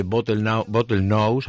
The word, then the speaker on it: Bottlenose: Tenemos con nosotros a Bruno Díaz, que es el fundador y director de Bottlenose, a ver si lo digo bien, Dolphin Research Institute. No sé si lo digo bien, Bruno. Buenas tardes. – Buenas tardes. Perfectamente.